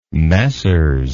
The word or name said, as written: messrs